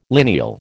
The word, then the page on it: lineal: American English